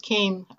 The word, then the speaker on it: Kane: In English, I pronounce it Kane. Actually, it was Yana Kane, but we spelled it like this in English, and that's how everybody pronounces it, and that's how it is.